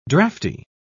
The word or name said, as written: drafty